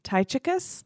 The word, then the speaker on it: Tychicus: But there's another C. – Tychius? – Oh, you're right.